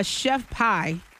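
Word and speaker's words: Chef Pii: This girl, a Chef Pie. That's how you say her name.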